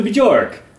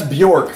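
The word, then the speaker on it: Björk: I'm like, oh, cool, a new Bjork. I know I'm saying that wrong. – That's Bjork for all of you listening at home.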